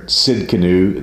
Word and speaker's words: Tsidkenu: And in the last word there, Tsidkenu, there's a silent T. It's T-S-I-D-K-E-N-U.